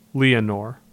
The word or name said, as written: Leonore